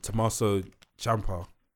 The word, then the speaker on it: Tommaso Ciampa: And Tommaso Ciampa, I said his name right, thank you.